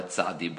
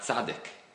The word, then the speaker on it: tzadi: Im tomar shahre ein kriyas ha'os tzadik el tzadi below kuf. If you will claim that the letter is supposed to be pronounced as tzadi and not tzadik, as he writes in the Sefer, he calls it the Os Tzadik, below kuf.